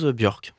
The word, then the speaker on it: Björk: la chanteuse Björk, si je prononce bien. – Oui, enfin j'espère.